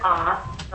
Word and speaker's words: Auth: My name is Margaret Oth. The last name is spelled A-U-T-H.